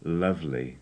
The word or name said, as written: lovely